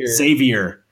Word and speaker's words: Xavier: Xavier. – Versus number 9, Florida State. Who do you guys got in this one? I'll let you guys lead it off. – Xavier for sure.